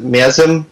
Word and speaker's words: Miasma: But with Bio, I can't even pronounce it, Miasma.